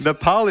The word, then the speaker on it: Nepal: Nepal is actually the correct pronunciation.